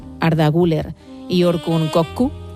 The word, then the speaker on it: Güler: Creo que Arda es Arda Güler. – Ah, Güler. Y como he dicho, Güler.